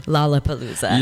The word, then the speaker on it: Lollapalooza: Lollapalooza.